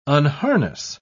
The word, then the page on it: unharness: British English